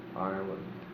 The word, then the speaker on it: island: Also, I can remember in first grade, a confrontation with the teacher because I insisted on pronouncing the word Island. And she had great difficulty convincing me that the S was silent, and I insisted that if the S was there, why did they take it out if they wanted to just be Island?